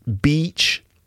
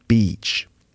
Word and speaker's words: beach: Bitch, the short I sound, eh, bitch. And then beach, that long E sound, E, beach.